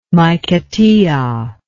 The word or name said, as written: Maiquetia